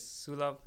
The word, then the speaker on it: Sulov: So my name is Sulov Khadka.